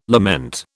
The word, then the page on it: lament: British English